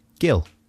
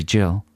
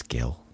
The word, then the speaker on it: Gill: How would you pronounce the name G-I-L-L? – Gil. – From Essex. Could be Jill. – It's Gil.